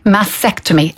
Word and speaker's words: mastectomy: Please pronounce the T in mastectomy. – Mastectomy. Yeah. And even to spell it is M-A-S-T. – Yeah, E-C-T. – Yeah, I was kind of sliding over the T there.